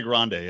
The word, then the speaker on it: Grande: Or Grande. Is it Grand? Grande. How do you say her last name? Grande? – Ariana Grande, yeah.